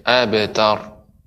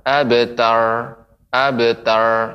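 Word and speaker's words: أبتر: Tapi pengucap, nah, pengucapan ra ibu, Jangan juga tidak ada getaran. Abetar, abetar itu roknya tidak bergetar. Abetar, cuman roknya kita tegangkan. Kita keraskan sehingga dia abetar. Tidak juga. Dia harus ada getaran tapi getaran sedikit.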